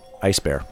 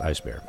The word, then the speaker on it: E1SBAR: it's spelled E1SBAR. – Ice Bear. – And pronounced Ice Bear.